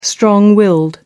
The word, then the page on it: strong willed: American English